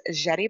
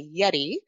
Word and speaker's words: Yeti: So my name is Yeti Beth Morales, and I go by Yeti. The easiest way to pronounce it is like the cooler cups. So I did not invent them, but Yeti, if you can remember the cooler cups, you got me.